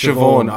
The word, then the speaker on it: Siobhan: Anyway, it's spelled Siobhan, pronounced Siobhan.